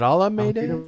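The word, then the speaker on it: Almeida: Is it Alameda? What's that? – Yeah, Alameda was my side, as apparently the Brits say, my cheeky side bet.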